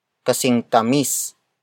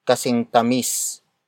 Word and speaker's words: kasing tamis: The next word is kasing tamis. Kasing tamis. Kasing tamis. Kasing tamis is spelled as K-A-S-I-N-G-T-A-M-I-S.